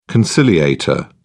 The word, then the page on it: conciliator: British English